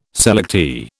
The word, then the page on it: selectee: British English